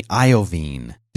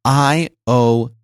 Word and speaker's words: Iovine: Did you know that's how you're supposed to say his name? – I did not know that. – Jimmy Iovine. – Iovine. – Like Jeffrey Veen. Iovine. – Not like iodine. – Not like iodine, but just with a V, Iovine, which is how I thought you said it.